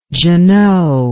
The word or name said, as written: giono